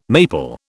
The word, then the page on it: maple: British English